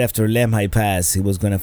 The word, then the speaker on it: Lemhi: I mean, we wanted to cross this area where Lewis and Clark for the first time crossed the Continental Divide, and that's I believe it is pronounced Lemhi Pass, although the Google lady pronounces it Lemmy, like Lemmy Pass, but Lemhi seems to be the consensus.